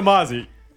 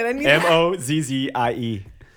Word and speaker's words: Mozzie: well, what's a Mazi? M Mazi. – How do you spell it? – M O Z Z I E.